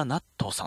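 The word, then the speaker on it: 和田夏十: そして、作詞をされた和田ナットさんという方ですね。夏に銃と書いてナットと読むという、すごいかっこいい名前。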